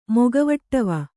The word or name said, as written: ಮೊಗವಟ್ಟವ